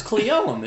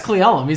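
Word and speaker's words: Cle Elum: It's Cleelum, isn't it? – Yeah, it's Cleelum.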